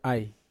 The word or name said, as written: ai